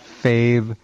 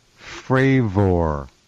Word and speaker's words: Fravor: It's actually not Fave. It's Fravor, which is even stranger.